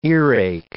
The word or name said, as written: earache